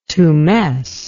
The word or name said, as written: tumesce